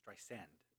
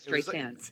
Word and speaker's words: Streisand: Streisand. – Yes, Streisand.